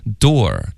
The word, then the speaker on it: door: 所以重音上有区别，一个是哒哒，一个是哒哒 OK，第二呢发音上区别还是挺大的， door 的这个发音呢是一个倒 C 的这个元音后面带一个 R， OK，然后它的发音就是 door，